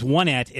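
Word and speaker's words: Onett: soundtrack is much superior, and I know I just angered the other half of the internet that was already angered at my pronunciation of Onet, or Onet, because it is Onet. I said Onet, but it's in Earthbound, it's Onet and Tucson and Threed and Foreside. Get it? 1, 2, 3, 4.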